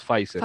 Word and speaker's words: Pfizer: en España pronuncian Pfizer, aquí en Latinoamérica casi siempre decimos Pfizer.